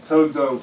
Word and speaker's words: sozo: That word is pronounced sozo. Okay? That's why the doctrine of salvation in Scripture, doctrine of salvation is called soteriology. Okay? The other word that sozo comes from is soter, which means to save.